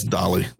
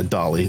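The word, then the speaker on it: Dali: That's Dolly. That's D-A-L-I, Dolly. Salvador Dolly.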